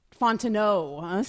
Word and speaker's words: Fontenot: Fontenot, silent T there. It goes with the French pronunciation.